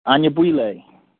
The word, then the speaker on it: Anyabwile: Anyabwile.